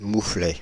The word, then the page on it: mouflet: [mu.flɛ]; /mu.flɛ/